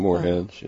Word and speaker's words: moorhens: Harlequin ducks, mohens, and swans. – Moorhens and swans, right.